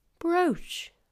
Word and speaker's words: brooch: And then there's also the double O in words like brooch, brooch, piece of jewellery that you wear on your lapel.